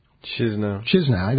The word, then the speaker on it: Chișinău: Chișinău. – I always get it wrong. They're going from Chișinău to Bucharest.